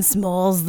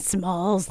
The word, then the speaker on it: Smalls: I'm Karen Smoles, the medium. – Smoles, huh? – Smoles. Can you spell that for the audience? – S-M-A-L-L-S. Karen Smoles, the medium.